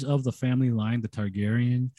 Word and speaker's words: Targaryen: Targaryen.